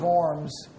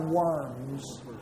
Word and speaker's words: Worms: In 1521, he was summoned by the Emperor Charles V to Worms to recant his views. You know, Germans pronounce, the W's pronounce V, so that's not Worms, that's Worms.